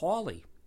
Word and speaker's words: Halley: There's some evidence that Edmund Halley pronounced his last name, or at least that others pronounced his last name, as Hawley, as if it referred to a house with a lot of halls in it, a Hawley house.